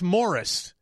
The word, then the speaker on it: Maurice: And it's Morris. It's spelled like Maurice. – Why do I keep thinking Maurice? – Well, it's spelled that way because that's the way it's spelled. But it's pronounced, they're Australian.